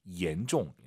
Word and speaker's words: 严重: 严重. – 혹시 지금 마음속으로 얀종하신 분 계십니까? 중국어의 얀이란 발음은 없어요. Y를 I로 바꾸세요. I-A-N, E-N입니다. 실제로 한글 이로 시작하는 발음이에요. 얀이 아니라 얀, 얀종.